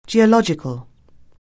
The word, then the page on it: geological: anglais